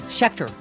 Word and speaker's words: Schechter: Cliff Schechter is Schechter. I said it right.